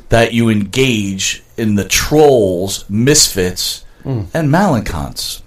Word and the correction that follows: malcontents: Am I saying that right? – Malcontents.